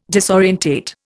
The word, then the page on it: disorientate: Indian